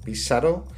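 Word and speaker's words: Pissarro: Sí, bueno, pues hay tres grandes autores impresionistas, son Monet, Pizarro, Pizarro, eh, que realmente se pronuncia así, y Sisley.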